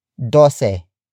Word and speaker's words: dose: And that is dose. Dose. Dose. Dose is spelled as D-O-S-E.